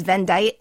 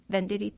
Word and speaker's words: Venditti: I didn't connect how his name was written to how it sounded. I thought it was Vendite, and I learned that from you. And he's, I guess, making people incredibly aware of his pronunciation because his nickname is P. Vitti, which I think is really cute, right? Venditti, P. Vitti? – Adorable.